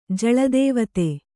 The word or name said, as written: ಜಳದೇವತೆ